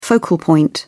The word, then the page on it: focal point: British English